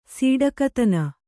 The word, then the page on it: ಸೀಡಕತನ: sīḍakatana